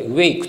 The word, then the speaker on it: WAIC: はい、えっ、ー、と、 WAIC と書いて WAKE と書くんですが、